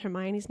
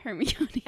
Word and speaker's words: Hermione: But Olivia, in the period when I was really not loving her, would pronounce Hermione's name Hermione. – Hermione. – And I was like, Olivia Bierman, it's not Hermione, it's Hermione.